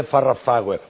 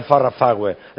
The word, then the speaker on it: Farrah Fawcett: Que ahora, joder, yo tengo 7 sobrinos que me dicen, no se dice Farrah Fawcett, porque ahora todo el mundo, todos los niños hablan inglés. Nosotros no hablamos inglés, joder. Entonces era, tío, no se dice Farrah Fawcett, es Farrah Fawcett.